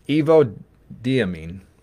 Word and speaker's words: evodiamine: And the I said it right the first time, evodiamine. That's pretty good.